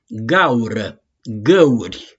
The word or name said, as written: GAURĂ